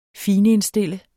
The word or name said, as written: finindstille